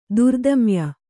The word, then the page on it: ತಾರತಮ್ಯ: tāratamya; Kannada